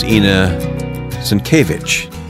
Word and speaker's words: Inna Sinkevych: My guest is Inna Sinkevych.